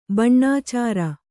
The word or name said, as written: ಬಣ್ಣಾಚಾರ